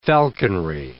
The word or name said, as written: falconry